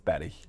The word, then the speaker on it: Bury: But it's Betty. – Is it Betty? – It's Betty. I mean, you would never think that, but that. – Why don't you spell it B-E-R-R-Y?